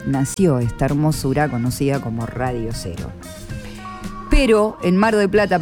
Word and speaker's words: Radio Cero: Lo digo así, Radio Cero. Porque nuestro cero se escribe con Z